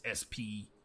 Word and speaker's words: Spreaker: By the way, you can type in Big Man Bias and Spreaker, which is spelled like speaker, but it's Spreaker.